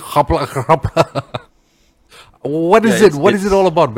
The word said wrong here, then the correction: Rapla: It's Gapla. So the actual spelling is R-A-P-L-A, Jinga, J-I-N-G-G-A, right? So the way we pronounce the R sound is, it sounds Ga, you know, and that's how in Penang, you know, right up to Perlis and all that, you know, you hear people pronouncing the R tone like that.